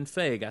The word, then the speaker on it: Feige: Feige.